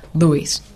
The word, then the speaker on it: Lewis: Puedes decir Lewis, pero sí. Ellos pronuncian Lewis.